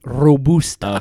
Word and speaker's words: Robusta: And you pronounced it wrong. It's Robusta.